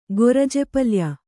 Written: ಗೊರಜೆಪಲ್ಯ

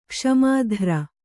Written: ಕ್ಷಮಿತೃ

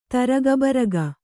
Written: ತಾರಗಬೀರಗ

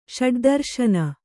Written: ಷಡುದರುಶನ

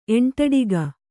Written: ಎಂಟಡಿಗ

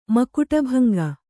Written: ಮಕುಟಭಂಗ

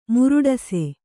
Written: ಮುರುಟಿಸು

